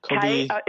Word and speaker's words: Kobi: Kobe Yamada, is that – or yes, Kobe. It's spelled differently. He is Canadian, but Japanese surname. Mm-hmm. K-O-B-I, and his surname is Y-A-M-A-D-A.